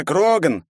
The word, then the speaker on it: Кроган: Я Кроган! С ударением на первый слог.